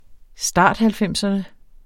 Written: starthalvfemserne

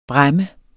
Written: bræmme

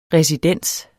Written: residens

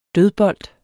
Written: dødbold

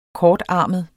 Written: kortarmet

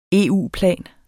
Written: EU-plan

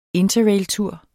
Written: interrailtur

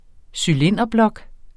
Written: cylinderblok

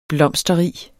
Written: blomsterrig

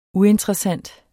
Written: uinteressant

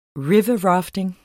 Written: riverrafting

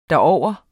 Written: derover

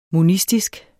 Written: monistisk

